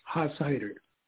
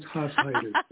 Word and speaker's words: Hashider: Our family here where I live pronounces it as Hashider. It's good old German. Used to be double S, but one got dropped over the years. So it's Hashider.